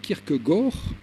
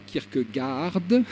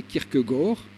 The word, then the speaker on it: Kierkegaard: Je vais prononcer son nom à la danoise, donc Kierkegaard, et non pas Kierkegaard, mais Kierkegaard.